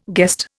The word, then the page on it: guest: Indian